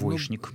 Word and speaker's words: двоечник: Ну, десяток, может быть, полтора слов, в которых требуется произношение «ш» на месте буквы «ч». Это «что» и его производные, «конечно». Это скучно. – Скучно. Ну, вот яичница, булочная. – Двоечник. – Ну, двоечник. Но булочная уже иногда произносит и так, и так.